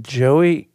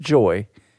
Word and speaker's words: Joy: And like, Joey? No, not Joey, because this is Kentucky. People think Joy is two syllables. It's just Joy.